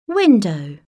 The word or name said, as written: window